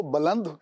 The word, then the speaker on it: بلند: میں نے لفظ بلند کو بلند کہا با کی زبر کے ساتھ، یہ ایک فصیح تلفظ ہے،